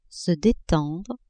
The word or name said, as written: se detendre